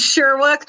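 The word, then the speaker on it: Shyriiwook: It was Shriwook. – Shriwook. Yeah. Yeah. – That's the name of their language anyway. – It's Sherwook. I'm being corrected by my fiance. Oh, it's pronounced Sherwook.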